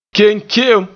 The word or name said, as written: ke'enke'em